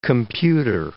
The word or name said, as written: computer